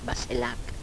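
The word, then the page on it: baselak: dialetto croato di Lukezi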